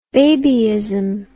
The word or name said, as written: babyism